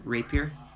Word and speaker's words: rapier: I'm going to use my Reaper. – Your rapier? – Rapier. – That's how it's... That's how you say it.